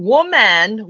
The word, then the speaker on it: Woman: Let me just say this for everyone that might not hear the correct pronunciation of the organization of the movement. It's Million Woman, which is W-O-M-A-N.